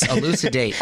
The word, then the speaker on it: elucidate: eludidate elucidate. Are we pronouncing these right? Yes, elucidate. elucidate.